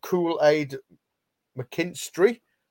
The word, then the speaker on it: Kool-Aid McKinstry: Ali's gone for Kool-Aid McKinstry.